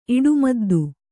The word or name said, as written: ಇಡುಮದ್ದು